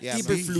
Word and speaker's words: Eberflus: Matt Eberflus. – He even fucking pronounces it perfect way. – The Swedish way that it is.